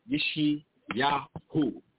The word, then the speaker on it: Yeshayahu: And we in the prophet Yeshayahu, four syllables, Yeshayahu, Yeshayahu, Yah is salvation.